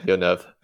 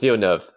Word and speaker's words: Villeneuve: Villeneuve. It's Villeneuve.